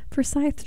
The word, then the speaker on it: Forsyth: Well, you are listening to your Sunday Startup this morning on WZYN Radio in Valdosta, Georgia, and Forsyth, Georgia.